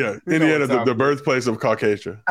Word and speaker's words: Caucasia: How do you pronounce? Is it Caucasia? – Caucasia.